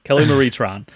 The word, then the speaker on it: Kelly Marie Tran: Kelly Marie Tran.